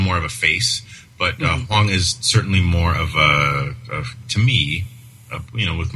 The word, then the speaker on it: Hwang: I butcher this one. Jung Min Hwang? – Hwang. Yeah. – Wrong pronunciation of the A there.